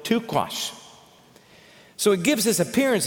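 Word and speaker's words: taqsh: It's pronounced tuquash, but it's T-A-Q-S-H.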